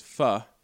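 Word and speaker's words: phở: I hate when people say it this way, but the correct way to pronounce it is pho.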